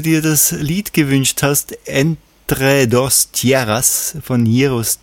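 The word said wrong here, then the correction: Héroes: del Silencio. – Heroes, oder? – Sagt du, ja, Heroes ist besser.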